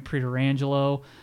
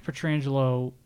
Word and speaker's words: Pietrangelo: It's spelt like Pietrangelo. – Well, they are related. I think they're like second cousins, if I'm not mistaken. – And I've heard Frank Pietrangelo being announced or being pronounced Pietrangelo. So I'll just say Frank Pietrangelo.